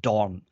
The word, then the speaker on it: Dorn: Is it D-O-N-G? Like a dick? – Dorn. – D-O-R-N. Oh, Dawn. Dawn. It's the Miss Doubtfire accent.